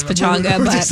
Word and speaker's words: Pechanga: Pechanga.